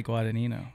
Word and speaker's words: Guadagnino: Yeah, and I will say to start off that you were probably one of the first people to interview me that pronounced my last name correctly. Because you're Italian. Because they don't understand how to say Guadagnino.